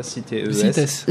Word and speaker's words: CITES: Exactement, et du coup, en fait, donc il y a la CITES, c'est ça ? Ça se prononce comme ça, CITES.